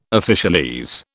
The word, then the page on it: officialese: British English